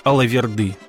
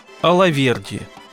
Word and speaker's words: Алаверди: Со служением «тамады» непосредственно связано правильное понимание возгласа «Алаверды». Кстати, в Грузии его произносят так – «Алаверди».